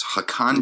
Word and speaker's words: Hakan: Hakan.